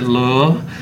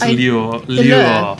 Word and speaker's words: lure: Sometimes I pronounce it lure, which is definitely wrong. It's lure.